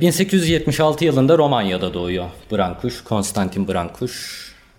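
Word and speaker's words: Brâncuși: Bu arada Brankuş diye okunuyor adı.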